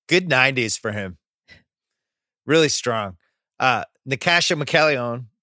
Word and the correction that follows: Natascha McElhone: How do you say it? – I think it's Natasha McElhone. Yeah.